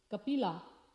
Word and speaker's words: Kapila: So it from the Mahabharata describes that how Asuri, Brahmana, he was very learned, and he had a wife whose name was Kapila with a long A.